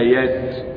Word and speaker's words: يسر: يسر، لأن أصل كلمة يسر يسري، لكنها محذوفة. إذا نظرت لهذه الكلمة في صوت الفاجر لوجدت كلمة يسر أخرها راء ليس ياء، فهي محذوفة فلا تنطق بها.